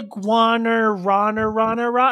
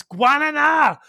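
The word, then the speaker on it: Guaraná: They all say like, what's the Guanarana? And I'm like, it's Guananá.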